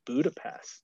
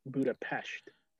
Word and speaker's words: Budapest: But in terms of my favorite place to visit on that trip might have been Budapest. Yeah. Which is I'm actually saying it wrong. Budapest is actually pronounced Budapest. – Interesting. – With an SH.